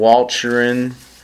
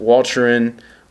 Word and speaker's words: Walcheren: Later at Flushing and Walcheren, I butchered that, Walcheren, wounded as he was near me, he refused to be evacuated.